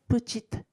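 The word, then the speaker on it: petite: で、女性系だとさ、あの、後ろにうがついて発音が変わってプチッになります。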